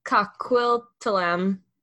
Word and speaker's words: Coquitlam: So they owned a farm in Port Coquitlam, that's not pronounced correctly, British Columbia. Huh? It's Coquitlam.